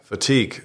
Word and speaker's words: fatigue: Kennst du den Begriff Meeting Fatigue, wenn ich es richtig ausgesprochen habe? Fatigue, ja, Müdigkeit, ne? – Genau.